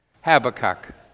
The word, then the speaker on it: Habakkuk: Now, the prophet Habakkuk, how do you say that? Habakkuk? Habakkuk. What? Our resident theologian, how should it be pronounced? Habakkuk.